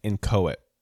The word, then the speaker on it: inchoate: Also, it's pronounced inchoate. I just looked it up, which was close because I almost looked real dumb.